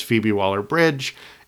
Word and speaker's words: Phoebe Waller-Bridge: It has Phoebe Waller-Bridge in it, which I think will be...